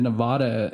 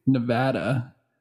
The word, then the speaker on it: Nevada: Well, I said Nevada until very recently, until I got in trouble. – And what is it supposed to be? – Nevada.